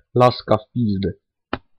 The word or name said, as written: laska w pizde